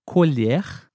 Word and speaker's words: colher: Na segunda frase, a palavra colher foi pronunciada um pouquinho diferente também. Perceberam? Colher é um objeto, utensílio. Colher. E o verbo colher. Colher. Um pouquinho mais fechado.